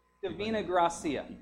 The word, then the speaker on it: Divina Gracia: Our missionary of the week is Brother Peter, and I'm not Divinai Gracia, and I probably totally butchered his name. Say it again. Divina Gracia, from the Philippines.